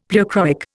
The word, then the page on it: pleochroic: Indian